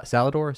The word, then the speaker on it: Salladhor: I always say his name wrong. Sal- Salladhor, Salladhor, Saan, yeah.